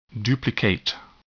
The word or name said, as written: duplicate